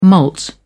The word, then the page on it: malt: British English